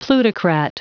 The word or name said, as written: plutocrat